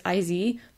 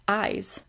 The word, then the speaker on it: IVE: And that's IVE, which I didn't realize was how you pronounced it. I thought it was IZ, but no, it's Eyes.